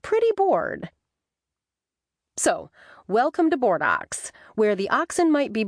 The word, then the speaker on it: Bordeaux: We don't pronounce our name like our French sister city either. Some bright ancestor of mine who settled this valley in the 1800s pronounced our town name Bordeaux. Yep, like some bull standing out in a field wondering what to do with his day.